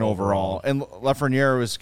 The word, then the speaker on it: Lafreniere: Alexis Lafreniere.